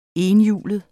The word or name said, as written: ETHJULET